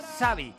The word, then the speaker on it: Xabi: Es un nombre en euskera y su forma de pronunciar es Xavi.